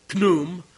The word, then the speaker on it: Khnum: Yeah, Khnum. That's how it's pronounced.